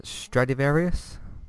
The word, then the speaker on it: Stradivarius: So this is for Season 9, Episode 7, Stradivarius? – Yes. – Yes, that's how you say it. – That's how you say it, yes.